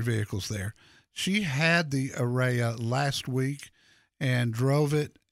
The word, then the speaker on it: Ariya: But the car that we've been looking for from Nissan is the Ariya, and I hope I'm saying that right. A-R-I-Y-A, Ariya.